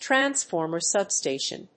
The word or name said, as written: transformer substation